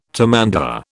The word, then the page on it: tamandua: British English